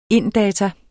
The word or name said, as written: inddata